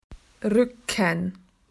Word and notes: back: Australian English

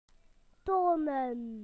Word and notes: to show: British English

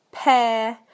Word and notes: pair: British English

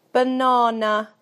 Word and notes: banana: British English

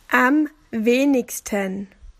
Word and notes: least: Australian English

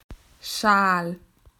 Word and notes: scarf: Australian English

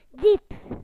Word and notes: deep: British English

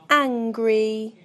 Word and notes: angry: British English

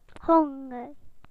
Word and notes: hungry: British English

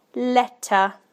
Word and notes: letter: British English

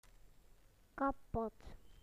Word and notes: broken: British English

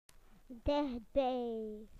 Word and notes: near: British English

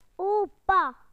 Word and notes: grandfather: British English

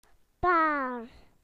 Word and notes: pair: British English

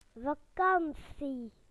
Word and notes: holiday: British English

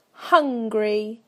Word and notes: hungry: British English

